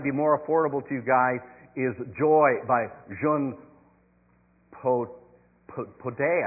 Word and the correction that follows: Jean Patou: I think it is. And that particular one, it's Jean Patou. Sorry. I said it wrong, probably.